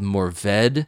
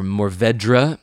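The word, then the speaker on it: Mourvèdre: And Bandol is famous for really these full-bodied reds from the grape called Mourvedre, or Mourvedre, if you pronounce all the letters, and you'll get to hear the French guys say it properly.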